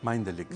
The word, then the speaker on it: Mindelink: No sé si se pronuncia así. – Mindelink. – Mindelink. – Sí. – Ah, es mucho más inglés que de lo que yo pensaba.